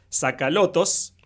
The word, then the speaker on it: Tsakalotos: Y entra un tipo que también participaba en las negociaciones, que era como el coordinador de las negociaciones, se llama Euclides Tsakalotos.